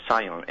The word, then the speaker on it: scion: It's also called a Scion, S-C-I-O-N, pronounced exactly like Zion.